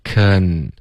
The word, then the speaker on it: can: OK，这个 can 开头嘴巴要张开，可舌尖再顶着上额 can，而不是 king king，这是一个非常中式的一个发音。